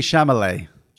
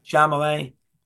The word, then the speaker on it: Chalamet: Timothée Chalamet? – Timothée Chalamet. – Chalamet.